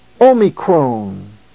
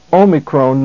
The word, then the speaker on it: Omicron: Beginning with the first of the six letters in this lesson, the first letter is the letter Omicron. It is pronounced O, as in the word obey.